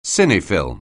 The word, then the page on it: cine-film: anglais